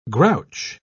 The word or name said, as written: grouch